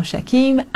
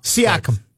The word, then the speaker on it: Siakam: Because Siakam, then you get Shake what? – Then it rhymes with the dream.